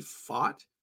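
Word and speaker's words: Pfaadt: Is it Fate? – Fat. it's I'm pretty sure it's actually Fought, but we call him P-Fat for sure.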